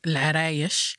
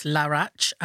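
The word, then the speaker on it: Larache: It's called Larayesh. Mm. That's how you say it in Arabic. But, like, in English, it's Larach.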